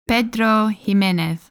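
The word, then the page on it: Pedro Ximénez: Spanish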